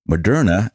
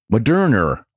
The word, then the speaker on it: Moderna: My New York accent combined with dry mouth resulted in me pronouncing Moderna as Moderner last week.